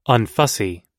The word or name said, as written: unfussy